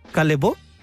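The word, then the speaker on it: Callebaut: Y ella trabaja con una marca que se escribe Calle Baut. Pero es una marca francesa que se dice Calebo.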